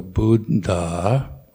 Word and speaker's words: Buddha: And then another difficult one try to pronounce both letters of a double consonant. So Buddha or Sabha, Satta. Um, one trick is to put a little, uh, like microscopic millisecond um, space between the two letters, Buddha, Sabha, Satta.